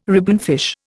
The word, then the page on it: ribbonfish: Indian